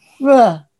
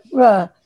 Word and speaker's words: Freur: They were called, and you were supposed to say it as a sound, not as a name. They were called Fruh. And you're supposed to say it like Fruh. Actually, it was spelled F-R-E-U-R.